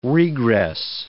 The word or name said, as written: regress